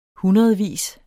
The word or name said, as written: hundredvis